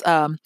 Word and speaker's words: Cynefin: And the name is Kyphin, and it's spelled C-Y-N-E-F-I-N.